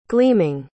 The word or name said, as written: gleaming